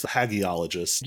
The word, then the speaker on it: hagiologist: I usually go with hagiologist.